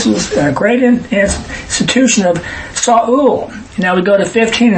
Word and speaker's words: Saul: And he had a son whose name was Saul. It's really two syllables, not one Saul. Saul,